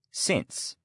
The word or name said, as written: now